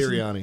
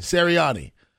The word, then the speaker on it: Sirianni: AP Coach of the Year, Brian Daboll, Sean McDermott, Doug Peterson, Kyle Shanahan, and Nick's, how you pronounce Sirianni. – It? Sirianni.